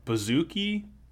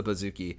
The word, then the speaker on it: bouzouki: called the bouzouki, I think it's pronounced.